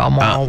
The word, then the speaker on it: Balmoral: Balmoral. – Balmoral, yeah.